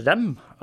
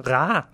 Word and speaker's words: Reims: Og når det gjelder ræ, så er dette et stedsnavn jeg har hørt uh, uttalt på fransk nå. Uh, I forrige episode uttalte jeg det rem, uh, hvor det skrives da r-e-i-m-s. Og hele ordet er stumt. Det er altså ræ.